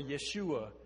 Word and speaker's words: Yeshua: We know that, which in Hebrew means Joshua, or would be pronounced Joshua or Yeshua, which means God saves.